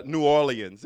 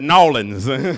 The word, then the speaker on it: New Orleans: Like, you guys said New Orleans. That was just so proper. But from Mobile, Alabama, we said Nawlins. It's Nawlins. It's just too many syllables in there, right? We just had to take some of that stuff out.